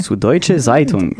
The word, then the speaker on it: Süddeutsche Zeitung: Süddeutsche Zeitung. – Süddeutsche Zeitung.